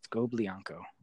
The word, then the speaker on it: Scoblionko: Scoblionko.